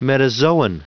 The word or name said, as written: metazoan